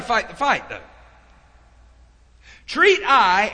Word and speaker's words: Ai: Now that's, I even looked up a pronunciation. I've been saying it wrong all these years. It's Ai. E-Y-E is the way that city is pronounced.